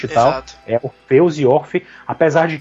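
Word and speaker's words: Orphée: É, é porque assim, o, o, o Orfe, é como se fala o nome do Orfeu no francês.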